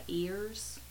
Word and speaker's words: Ayers: He may have gone by Ears under the English pronunciation. – Is this an actual Catawba name, or is it a trader who married in and they took the trader's name or whatever? – Well, the common thought is that this colonel, his name was, he actually used Ears to identify himself. And when the colonists started using it, they just transformed it to Ayers. And it may be that's how phonetically it sounded.